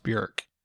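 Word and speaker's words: Björk: sorry i'm pronouncing incorrectly i did that for years and i always fail to correct myself it's pronounced bjork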